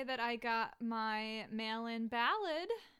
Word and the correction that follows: ballot: So how do you say it? – Ballot. – Ballot. – I mean, if you're a regular American, you'll just say ballot. But it's not ballad.